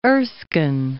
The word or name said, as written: Erskine